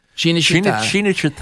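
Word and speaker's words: Cinecittà: Yeah, Shinashita. Yeah. Is that how they say it? – Shinashita.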